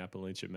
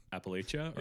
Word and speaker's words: Appalachia: Appalachia or Appalachia? Appalachia or Appalachia? – Yeah. I'm sure someone will correct us. – Yeah, it depends on who you ask.